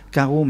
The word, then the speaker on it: Kharo: c'est le groupe Caro qui s'appelle Caro, mais que ça s'écrit K-H-A-R-O, avec peut-être de petites choses en plus,